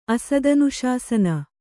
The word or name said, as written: ಅಸದನುಶಾಸನ